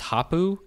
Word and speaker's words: Hopoo: But I guess it's Hoppo Games is how you say them? – Yeah, H-O-P-O-O.